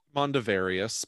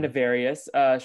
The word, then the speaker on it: Mondavarius: But Emil Mondavarian, did I say that right? – Mondavarius, but it's fine. – Mondavarius shows up and is like, I invited you to this island because spooky things are going on.